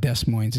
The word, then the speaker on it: Des Moines: And it's not Des Moines, it's Des Moines. – Des Moines. Des Moines. Des Moines. – The S is silent.